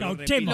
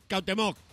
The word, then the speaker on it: Cuauhtémoc: no me digáis que Cauchemo, lo puedes repetir. – Con acento en la E. – No, es que no me ha quedado muy claro. – Tlatoani Cuauhtémoc.